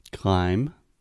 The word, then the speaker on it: climb: Climb, yeah. – Climb. – climb with a B at the end. – Yeah. We do not pronounce the B.